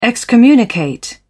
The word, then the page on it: excommunicate: British English